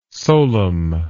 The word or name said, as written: solum